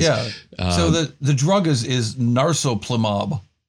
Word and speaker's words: narsoplimab: Like narsoplimab is, I believe, the correct pronunciation of it.